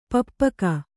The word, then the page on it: ಪಪ್ಪುಕ: pappuka